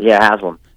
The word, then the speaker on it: Haslam: But Haslam.